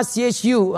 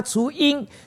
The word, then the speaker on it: 雏: 看到了，这老鹰能够背着这整个呢 baby 的鹰，好，叫做呢这个字怎么念，什么音？雏鹰，对吗？ CHU 啊，雏鹰。